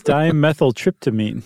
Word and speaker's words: dimethyltryptamine: Oh yes, it's dimethyltryptamine.